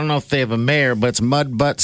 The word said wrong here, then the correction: Mud Butte: South Dakota. – Shut up. – Yeah. – Mud Butte. – I was going to say. – I pronounce it butt. – B-U-T-T-E.